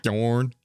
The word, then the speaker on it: Gjorn: I forgot how much I like hearing you say those names, like Viking Knife Gjorn. – Gjorn, y'all. – How would you say it, Micah? – No, that's right.